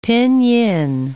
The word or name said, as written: pinyin